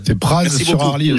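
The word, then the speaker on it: Praz-sur-Arly: Prononcez pas le Z, s'il vous plaît. – C'est Praz sur Arly aussi.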